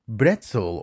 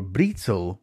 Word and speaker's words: Brezel: In German, the word is Brezel or Brezel with a B, but in English, they pronounce it with a P.